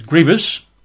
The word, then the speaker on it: grievous: By the way, the word is grievous. – Not grievous. – Two syllables, not grievous, which would have three syllables, and a lot of people say grievous. It's slightly annoying when one hears it. It's just grievous.